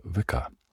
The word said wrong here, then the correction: Wicca: Is it vicar or is it Wicca? It's Wicca, isn't it? You pronounce the W. Wicca.